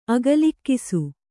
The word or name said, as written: ಅಗಲಿಕ್ಕಿಸು